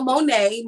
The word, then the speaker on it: Monet: It's Danielle Monet. Monet is spelled M-O-N-E-T. The T is silent. Don't do that.